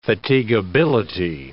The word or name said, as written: fatigability